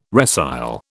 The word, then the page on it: resile: British English